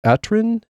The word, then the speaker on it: Aturen: It is the Aturen, A-T-U-R-E-N, I think is how you pronounce that.